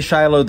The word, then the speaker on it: Shiloh: Scott Stripling runs this entire project at Shiloh. – Yeah, Americans say Shiloh, the rest of the world says Shiloh.